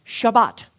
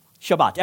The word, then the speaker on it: Shabbat: So that word rest is, it's translated from a Hebrew word that's pronounced Shabbat. Shabbat.